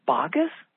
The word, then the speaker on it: asparagus: And I go, spagus? What is spagus? I've never heard of it before. And he goes, oh, that's if you're in New Jersey or grew up there, that's how you pronounce asparagus.